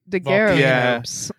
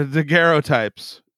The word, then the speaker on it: daguerreotypes: Daguerreotypes. – Yeah. Daguerreotypes.